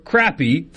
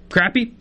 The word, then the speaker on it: crappie: First of all, both pronunciations of the fish are fine. Crappy, fine. Crappie, also totally acceptable.